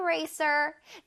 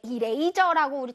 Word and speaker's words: eraser: Eraser는 내 이레이저라고 우리 친구들 많이 발음하더라고요. 이레이저, 이레이저 절대 아닙니다. 이레이저 선생님 별로 좋아하지 않아요. 자, one more time. Eraser 라고 발음해 볼게요. Eraser. 잘했어요. One more time. Eraser. 자, 쓰쓰쓰 소리를 강렬하게. Eraser.